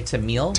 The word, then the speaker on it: Tamil: The fastest growing language in the United States is how do you pronounce it? – I would say Tamil.